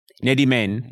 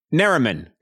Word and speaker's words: Nerimen: So there's actually two pronunciations. So if you want to pronounce it like an Arab, you're going to say Nerimen. – Nerimen, uh-huh. – Nerimen, okay. And if you want to pronounce it like how it's going to be easier for you to pronounce it, just say it if like Superman was like someone's last name. So like Bill Superman. So it's going to be Bill Nerimen.